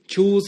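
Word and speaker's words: Chew: Chu, por si no lo escucharon la otra vez, es una historia de Tony Chu Chu se escribe Cheu, como masticar,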